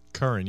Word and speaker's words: Curran: Curran. Did I say that right? – Curran, yeah.